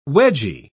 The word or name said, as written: wedgie